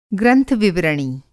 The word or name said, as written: ग्रंथ-विवरणी